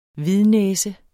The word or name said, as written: hvidnæse